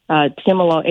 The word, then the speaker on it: Timolol: I don't even know if I'm pronouncing it right. Can he get off? – Did I do a good job? – Timolol, but Timolol is a beta blocker.